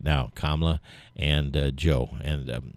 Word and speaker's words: Kamala: Well, yesterday after listened to the Barack Obama speech for Joe Biden and Kamala Harris And I said that right. I always say Kamala. I don't know why I make it a long, you know, a sharp A, but Kamala Harris.